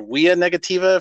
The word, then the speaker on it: Via Negativa: The book is called Via Negativa, or Via Negativa, if you're going to use the Latin pronunciation.